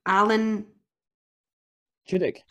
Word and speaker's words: Tudyk: Alan... – Tudyk.